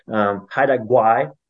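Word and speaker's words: Haida Gwaii: Haida Gwai is the pronunciation of the islands.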